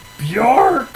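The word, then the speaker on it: Björk: Bjork!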